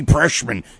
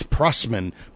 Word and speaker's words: Prussman: and Stacey Preshman. He never says her name right. It's Preshman, Prushman, Preshman. It's Prussman.